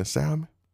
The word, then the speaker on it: salmon: I said, "No, I mean, you don't pronounce the L in salmon?" She said, "Why not?" I said, "Just how it is, Grandma. So going forward, it's salmon." She like, salmon.